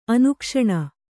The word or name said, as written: ಅನುಕ್ಷಣ